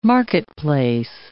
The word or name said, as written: marketplace